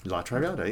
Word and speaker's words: La Traviata: For our listening audience, when we came recently to their latest production, make sure I'm pronouncing it correctly, La Traviata. – La Traviata, yes.